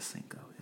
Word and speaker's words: Cinco: This is unrelated, but I've been doing a lot of Duolingo, learning Italian, and the way Cinco is spelled, you say that as Chinko in Italian. So I'm still in my Italian brain mode. So I said Chinko instead of Cinco.